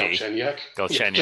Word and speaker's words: Galchenyuk: Galchenyuk.